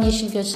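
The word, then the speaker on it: Yeşilgöz: Ik ben Dylan Yesilgus Segerius, nummer 5 van de VVD. – Ja. Daar hebben we het antwoord. – Ja, zij zegt Yesilgus. Yesilgus. Maar het is eigenlijk zonder klemtoon.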